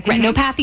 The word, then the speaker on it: retinopathy: If people want to know more about where you guys are and what you do, and especially the diabetic retinopathy. Am I saying that correctly? – Retinopathy. – Retinopathy. Thank you.